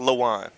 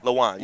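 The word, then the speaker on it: Lewan: You keep going hard on the lewd. I think it's just Luan. – Luan.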